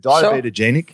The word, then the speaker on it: diabetogenic: Is it diabetogenic? – Sure. Perfect.